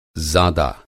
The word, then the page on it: ज़ादा: Hindi